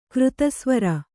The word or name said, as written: ಕಾರ್ತಸ್ವರ